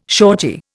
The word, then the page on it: shortie: Indian